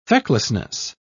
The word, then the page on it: fecklessness: British English